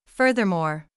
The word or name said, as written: furthermore